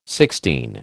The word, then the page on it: Sixtine: American English